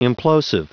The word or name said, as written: implosive